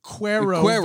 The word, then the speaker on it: quiero ver: No. It's pronounced quiero ver. Quiero.